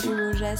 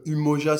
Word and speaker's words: Umoja: Euh, Umoja, ça se, prononce, bon, ça se dit, ça en français on dit Umoja. Après, vu que ça vient de la langue Swahili, en Swahili c'est plus Umoja. Mais après, Umoja, Umoja ça passe dans tous les cas. Mais on est habitué à prononcer Umoja.